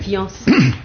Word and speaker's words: fiancé: Prononciation et lecture, conversation et lecture, c'est fiancé, c'est-à-dire deux syllabes. Bon, il ne faut pas prononcer un i, mais on prononce un iode, fian, une seule syllabe, voyons.